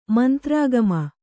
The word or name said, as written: ಮಂತ್ರಾಗಮ